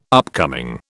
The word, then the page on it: upcoming: British English